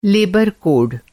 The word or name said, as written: लेबर